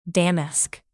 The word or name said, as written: damask